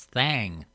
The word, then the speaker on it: Thang: I should pronounce that right. It's Thang, T-H-A-N-G, instead of Thing.